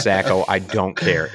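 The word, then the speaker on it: Sako: And you know, we've been pronouncing that wrong forever. – What? Seiko? – It's Sacco.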